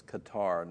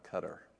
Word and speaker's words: Qatar: Johnny says it's Qatar, not Qatar. That's how they pronounced it when you were there. There's people pronouncing it otherwise now, is the issue.